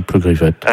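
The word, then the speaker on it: Pleugriffet: Comme ça se prononce, hein, Pleugriffette.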